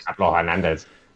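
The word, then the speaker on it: Pablo Hernández: Like, I very much was like, you pronounced Pablo Hernandez wrong. – Yeah, really wrong. – Yeah, Pablo Hernandez.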